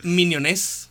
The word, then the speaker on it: Minionés: Minionés, con acento en la E.